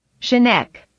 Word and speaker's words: Chaneque: Chaneque. – No, you. – Yeah. – I stick with Chaneque. – Okay. – Honestly. It's usually the hard ch in Spanish. – Plus the Q-U-E is K, right? – Yeah.